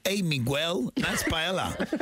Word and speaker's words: paella: So the thing is, like, paella is paella. Actually the meaning of paella means for her, para ella. So the word pronounced paella.